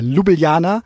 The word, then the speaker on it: Ljubljana: Und dann in, in, in Ljubljana, oder wie spricht man es aus? Ja, Ljubljana ja.